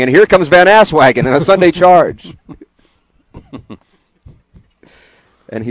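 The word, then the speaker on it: Van Aswegen: Lowercase V, Van. – Van? – A-S-W-E-G-E-N. – Van Asswagon. That's his name.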